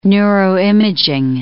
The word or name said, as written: neuroimaging